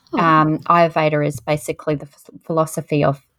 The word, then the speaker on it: Ayurveda: So you were talking about like imbalances in the body and addressing that with, is it, I'm going to say it wrong, Ayurveda? – Yeah. – That's yeah, it. Tell us about this. What is that? All yeah. – of that. – So Ayurveda has been around for about 5,000 years.